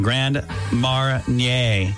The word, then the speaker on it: Grand Marnier: I looked up the pronunciation. Grand Marnier. – Marnier. – Grand Marnier. It's French, apparently.